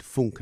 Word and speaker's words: Funck: The only surviving work by German composer David Funk, and that's spelled F-U-N-C-K. You look at it and you think, funk.